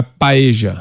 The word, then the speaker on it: paella: Na Argentina, a LL não se usa, é júvia, não é lluvia. – Entendi. – Cage, não é calle. – Então é paeja.